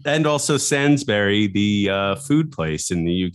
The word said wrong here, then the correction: Sainsbury's: Sainsbury's. – That's the name of it. It's S-A-I-N-S, not Sansbury. Sorry, I mispronounced Sainsbury.